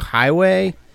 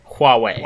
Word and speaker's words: Huawei: Is it Highway? – Huawei.